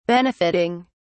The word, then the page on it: benefitting: British English